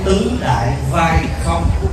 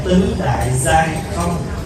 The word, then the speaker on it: tứ đại giai không: Không phải là tứ đại vai không, mà phải đọc là tứ đại giai không.